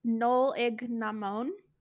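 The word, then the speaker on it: Nollaig na mBan: It's pronounced Nol Ig na Mon, I think is how they say it. But it means Women's Christmas in the Irish language.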